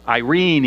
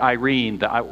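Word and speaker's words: Irene (εἰρήνη): And in the New Testament, the Greek word is Irene even. You can pronounce it Irene.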